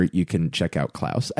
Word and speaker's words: Klaus: It's spelled Claus, but it's pronounced Klaus.